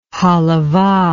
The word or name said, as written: halavah